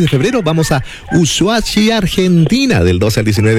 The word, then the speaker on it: Ushuaia: Es Ushuaia.